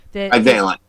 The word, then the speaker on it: bivalent: They received their first three doses of their original Spikevax vaccine, and this is a bivalent, am I pronouncing that right, vaccine? – Bivalent.